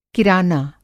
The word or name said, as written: किराना